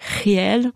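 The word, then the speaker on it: Geel: à Gael qui se prononce en réalité réel en flamand.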